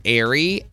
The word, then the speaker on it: Aire: So now she revealed that his name is Airy, A-I-R-E. It's pronounced air. – Just like oxygen. – Yeah, just like oxygen, so it's air.